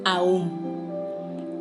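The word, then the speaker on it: Om: El día de hoy te voy a compartir cómo comienzo mis meditaciones con un mantra maravilloso, un mantra que se pronuncia aún, que es el sonido semilla universal, desde donde se dice surgieron todas las cosas.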